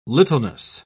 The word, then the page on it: littleness: British English